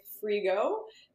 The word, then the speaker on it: Frigault: So on my Instagram account, Fit with Frigault.